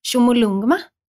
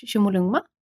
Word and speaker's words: Chomolungma: Och Mount Everest, eller som det faktiskt kallas i Nepal, är, nu kommer de här uttalen igen då, Chomolungma. Chomolungma.